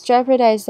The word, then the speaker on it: jeopardize: 再我们来看是 jeopardize， jeopardize 的发音呢比较特别，它是 g e o 这边是发 j 的音，j。 P-A-R 则是 per, jeopard, D-I-Z 则是 dies, jeopardize 是危机的意思。